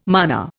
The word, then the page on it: mana: Indian